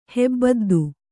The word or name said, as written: ಹೆಬ್ಬದ್ದು